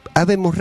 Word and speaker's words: Habemus: Y pueden hacerlo a Bemus Radio, Habemos Radio, sí, lo digo bien.